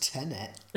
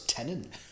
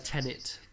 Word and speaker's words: Tenet: You were the one who said it's pronounced Tenet. Tenet or whatever. I thought it was Tenant. Yeah. There's only one N. – Just pronounce it as Tenet, and that'll be easier.